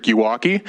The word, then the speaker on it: Yawakie: My name is Patrick Yawaki. That's spelled Y-A-W-A-K-I-E.